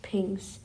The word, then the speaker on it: PGNCS: Außerdem war er Teil der primären Guidance Navigation and Control System, kurz PGNCS ausgesprochen, PINGS.